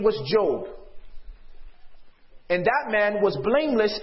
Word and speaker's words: Job: The name Job, which they don't have J's in Hebrew, it would be pronounced as a Y. So they would say Job.